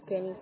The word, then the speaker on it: Kenny: My name is Kenny, spelled like K-E-N-N-Y, intro to my last name is Diaz and Charlie.